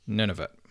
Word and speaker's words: Nunavut: Nunavut.